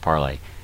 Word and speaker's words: Parler: And now they are warning, Andrew Torba is warning, that Parlay, or Parler as it's spelled, has bent the knee, as he's saying, to big tech. Parlay is now, it's spelled Parler, it's pronounced Parlay,